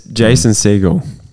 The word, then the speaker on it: Jason Segel: How good is Jason Segel? – He's very funny.